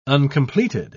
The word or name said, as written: uncompleted